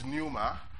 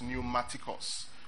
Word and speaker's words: pneumatikos: One is pneuma and one is tikos, pneumatikos. I'll spell that for you. P-N-E-U-M-A, pneuma, right? Tikos, T-I-K-O-S. So pneumatikos.